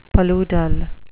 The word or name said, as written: paludal